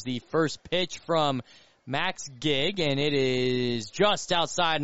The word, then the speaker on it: Gieg: G-I-E-G, pronounced Gigg, as Parker Kelly will stride to the plate.